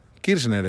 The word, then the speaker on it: Kirchner: Son los mismos que en Argentina no pueden pronunciar bien el apellido de la vicepresidenta. Fernández de Kirchner. Kirchner es. No es tan difícil. Pero muchos dicen Kirchner, Kirchner. Kirchner, entonces no lo pueden decir.